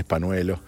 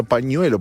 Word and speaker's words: pañuelo: Sí, pizza, P-I-T-S-A, pizza, así se pronuncia. Y Panuelo, se pronuncia pañuelo, porque tiene una ñ, es una ñ con un sombrerito. Se dice ñi.